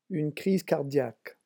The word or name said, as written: une crise cardiaque